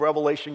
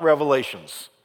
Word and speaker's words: Revelation: And could I just say, and I don't mean any disrespect by this, but a lot of times the book of Revelation gets mispronounced. Have you ever heard anybody call it Revelations? It is not Revelations.